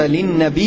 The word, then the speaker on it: للنبي: وقول الناظم للنبي هنا لابد أن تتنبه للفظها، تنطق ها هنا في النظم وما أضيف للنبي المرفوع بتخفيف الياء وسكونها، وذلك مراعاة للنظم والوزن حتى لا يحدث في هذا البيت الشعري زحاف أو علة.